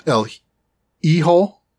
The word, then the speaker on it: El Hijo: Then the last game is called El Hijo, H-I-J-O. So it doesn't look like how it's pronounced. Because it does if you know Spanish, of which I know I don't.